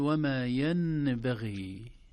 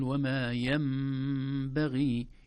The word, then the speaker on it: ينبغي: وما ينبغي لهم، في الآية 211، وما ينبغي لهم، حرف الميم فوق النون، وهذا يدل على أن النون انتقل مخرجها إلى مخرج الميم، لا تنطق نون أبدا، لا نقول وما ينبغي، لا نقول هكذا أبدا، بل نقول وما ينبغي. إذن النون الساكنة تنقلب إلى ميم إذا جاء بعدها حرف الباء، ويسمى هذا الحكم حكم القلب، أو أحيانا يسمونه الإقلاب.